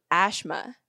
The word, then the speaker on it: Ashma: Mao, and people used to call me Ashma. Like A-S-H-M-A, like Ashma, that used to be my name because it was much easier to pronounce.